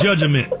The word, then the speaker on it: judgment: The word judgment is spelled J-U-D-G-M-E-N-T. – Okay. – Here's the way Kyle said. He said judgment. – I know. – I say judgment. He put that E in there.